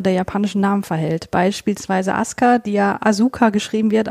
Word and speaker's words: Asuka: Also Asuka ist natürlich die, die richtige Aussprache. Hm, das liegt daran, weil das U im Japanischen oft so ein, ein sogenannter Schwundvokal ist. Das heißt, wenn du vorher einen harten Konsonantenton hattest, dann wird das oft verschluckt.